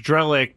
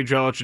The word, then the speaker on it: Drellich: He goes, hey, If my name's pronounced Drellick, but if they say Drellich, it doesn't matter.